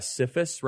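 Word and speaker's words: CFIUS: And it seems as if Congress and the kind of board, CIFUS, CIFUS, right? I want to make sure I pronounce that correctly,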